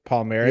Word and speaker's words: Palmieri: Did I say that right? Palmieri, yeah.